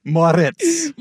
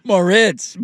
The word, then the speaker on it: Moritz: More tits, how do you say his name? – Moritz. – Moritz, my apologies. It looks like it's spelled that way.